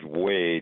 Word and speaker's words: Wei: Two professors at University of Wisconsin, one's named Wei, W-E-I, that's how I pronounce it. The other, Q-I-N, I'd probably pronounce that Quinn, but I may be wrong about that.